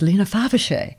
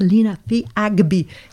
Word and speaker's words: Lena Fiagbi: So literally, when I was doing the programming, I had to phonetically pronounce who these people were, because Lena Fiagbi was Lena Faviche on air. And I went, no, it's Lena Fiagbi.